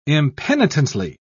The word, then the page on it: impenitently: British English